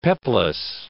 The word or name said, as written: peplus